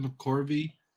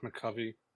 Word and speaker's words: McCovey: And a three-way tie for 20th place with Willie McCovey, Frank Thomas, and Ted Williams. – It's actually pronounced McCovey.